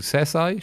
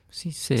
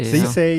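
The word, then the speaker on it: Cissé: Oder Sesai, spricht man Sesai aus? Sissé. – Sissé, ja.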